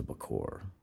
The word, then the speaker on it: Bokor: known as a Bokor. I believe that's the pronunciation. It's a B-O-K-O-R. – Yep. – So I can only imagine that it sounds like that.